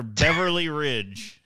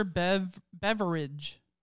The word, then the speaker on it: Beveridge: Dr. Beverly Ridge. – it's literally Dr. Beveridge. But it's not spelled like Ridge.